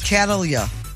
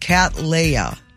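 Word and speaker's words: Cattleya: Catalia. Catalia. – Yeah. – It's a type of orchid and is pronounced Catleya.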